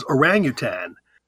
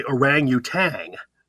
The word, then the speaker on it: orangutan: And again, it's by good English rules is orangutan. Most people say orangutan. They add the G at the end.